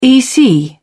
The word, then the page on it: ec: British English